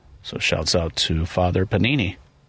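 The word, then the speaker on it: Pāṇini: Panini. – Yeah. That's my guess. Yeah. – It's a good guess. It's a long A sound. Yeah. – Mm-hmm. Panini wrote almost 4,000 verses describing rules of what are now fields of knowledge, such as syntax and semantics.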